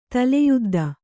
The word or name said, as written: ತಲೆಯುದ್ದ